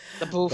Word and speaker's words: LaBeouf: LaBeouf. I don't know how to say his name. – LaBeouf.